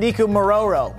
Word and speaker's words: Nikumaroro: So it was on Nikumaroro, right? – Very well pronounced.